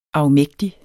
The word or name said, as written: afmægtig